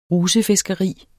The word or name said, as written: rusefiskeri